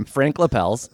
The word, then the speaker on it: Lapelles: Frank Lapels. – Frank Lapels. We got Penny Longmaster and Frank Lapels. – He has a habit. – But it's spelled LAPELLES. – Great. – It's a French name.